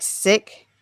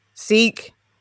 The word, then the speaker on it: Sikh: Apparently he's, am I saying it right? Sick? – Sikh.